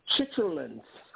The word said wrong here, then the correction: chitterlings: Chitterlings. – Oh, God. Moody noodles. – Chitterlings. It's pronounced chitterlings. It's chitterlings, man.